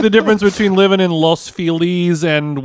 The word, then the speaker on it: Los Feliz: I appreciate the fact that you pronounced it correctly, Los Feliz.